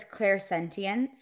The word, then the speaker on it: clairsentience: So there's clairsentience or clairsentience. Yes. People say it both ways. Both are correct.